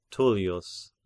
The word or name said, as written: Tullius